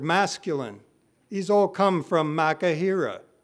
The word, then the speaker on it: machaira: The Greek word here is makahira. It's spelled, if you're taking notes, M-A-C-H-A-I-R-A.